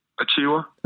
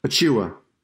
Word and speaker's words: Achiuwa: And help me out on the pronunciation of his last name. – Achiuwa. – Achiuwa. – Yeah.